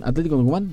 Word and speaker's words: Atlético Tucumán: ¿Atlético Tucumán? ¿Lo dije bien? – Pará, sí.